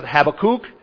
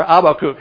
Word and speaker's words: Habakkuk: So whatever his parents were trying to call him, Habakkuk, some people pronounce it Habakkuk, or Abakkuk. There's a lot of different ways to pronounce this guy.